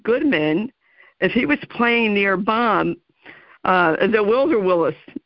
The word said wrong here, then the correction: Bob Wills: not sure how you pronounce his last name. – Bob Wills, Wills? – Yeah.